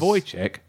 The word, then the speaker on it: Wojciech: The W at the beginning of Wojciech is pronounced with a V. So it's Wojciech.